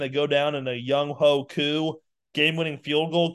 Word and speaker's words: Younghoe Koo: They say that the way to pronounce his name is Younghoe Koo. It's Younghoe.